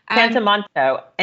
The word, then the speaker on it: Kantamanto: You talk about in the book a place called, I hope I won't butcher it, Kantamanto in Ghana. – Kantamanto.